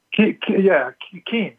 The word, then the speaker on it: Keynes: Keynes or Keynes? – Keynes. – K-E-Y-N-E-S is how it's spelled.